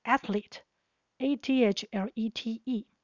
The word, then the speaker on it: athlete: 我们先来看 athlete，a t h l e t e 是指运动员，请留意它的发音，athlete，athlete，里面有 th 舌头要伸出来，后面两个 e 就 e 到底。